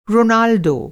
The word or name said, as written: रोनाल्डो